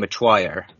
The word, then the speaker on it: Metoyer: Well, speaking of W's, is a guy that does not actually have a W in his name, but you say that there's a W in it. Brian Metoyer, but it's spelled M-E-T-O-Y-E-R.